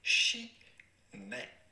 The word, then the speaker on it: Chimay: We are looking at how to pronounce with a typical French pronunciation or Belgian French pronunciation, Chimay. – Chimay.